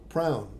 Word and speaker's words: Proun: P-R-O-U-N, so prune. Like noun with a PR from, like pronoun without the N, or whatever, how you were saying.